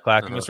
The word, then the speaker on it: Clackamas: He's got 33 acres on the, how do you say, Clackamas? – Clackamas River is 33 acres over there, and he's going to grow all his own food out there and try to live.